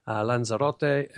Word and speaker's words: Lanzarote: Lanzarote and Reus.